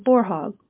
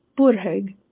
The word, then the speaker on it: Borhaug: My husband is Norwegian, and when I married him, I took his last name, Borhaug, which is the English pronunciation. It is actually pronounced Borhaug, which we quickly changed to Borhaug.